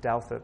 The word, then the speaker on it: Douthat: I can never say his name correctly, but it's Douthat. That's how you say it.